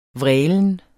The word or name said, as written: vrælen